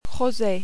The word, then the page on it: rose: French